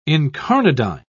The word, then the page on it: incarnadine: British English